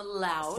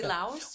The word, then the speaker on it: Laos: From Laos. – From Laos, yes. Pronounced Lao, if you're from Lao. – Oh, you don't say Laos? – Well, the French added the S because it sounded prettier. You know, that whole...